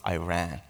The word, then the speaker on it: Iran: So, as Carl mentioned, I'm from Iran, and we pronounce it Iran. After 2 years, I learned to pronounce it Iran, like I ran.